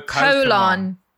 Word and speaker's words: Pokémon: Oh, you butcher it, James. Pokemon. – Oh, Pokemon.